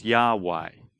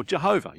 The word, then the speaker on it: Yahweh: Four Hebrew letters, which we pronounce as Yahweh or Jehovah. You can pronounce it as Jehovah.